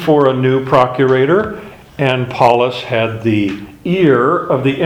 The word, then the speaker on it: Pallas: Paulus was the court favorite, and that's spelled P-A-L-L-A-S. So it's not P-A-U-L, it's P-A-L-L-A-S. Paulus was a court favorite.